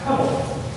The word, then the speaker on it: Hebel: And it's a word that in the Hebrew is pronounced Hebel. H-E-B-E-L. So you say it with Hebel.